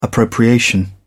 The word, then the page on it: appropriation: British English